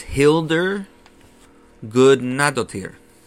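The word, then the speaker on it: Hildur Guðnadóttir: Uh, but this music, um, and the composer, I'm going to butcher this name, is Hildur Gudnadottir.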